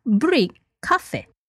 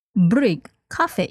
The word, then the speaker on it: bryggkaffe: And it is brygg kaffe. Brygg kaffe. It is spelled B-R-Y-G-G-K-A-F-F-E.